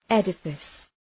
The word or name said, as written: edifice